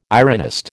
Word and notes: ironist: American English

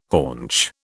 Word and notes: haunch: British English

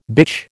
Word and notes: bitch: American English

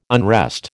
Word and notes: unrest: American English

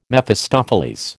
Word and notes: Mephistopheles: American English

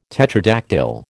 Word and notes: tetradactyl: American English

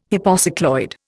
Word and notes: hypocycloid: Indian

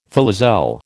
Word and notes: filoselle: American English

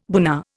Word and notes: buna: Indian

